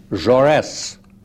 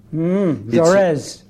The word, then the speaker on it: Jaurès: And my name, in order to right pronounce it, is Jaurès. – Jaurès. – Jaurès. – I see. – It's in honor of Jean Jaurès, the founder of the French Socialist Party and Humanitarian newspaper.